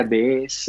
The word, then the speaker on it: DS: DS se pronuncia DS, es como diosa sí.